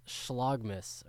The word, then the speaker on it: Schlagmas: What's this Schlagmas, or maybe I said that wrong. – No, that's right, yeah.